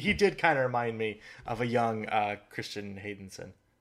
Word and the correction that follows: Hayden Christensen: I might have totally butchered that. Oh, Hayden Christensen.